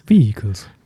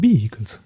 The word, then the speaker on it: vehicles: Vehicles. – Vehicles, wie man das ausspricht. Und wir auch vor allem. – Ja, auch die Betonung. Vehicles.